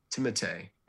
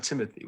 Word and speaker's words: Timothée: And that is how you pronounce it. Timothée is how you're supposed to say it. Not Timothy. We've been wrong.